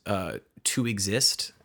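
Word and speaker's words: 2(X)IST: It is called To Exist, but it's spelled weird. It's like 2XIST.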